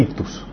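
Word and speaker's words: ictus (ἰχθύς): la palabra griega se pronuncia ah, ictus. Se pronuncia ictus y significa PES en griego y las iniciales del PES e significa Jesús, Hijo de Dios, Salvador del mundo.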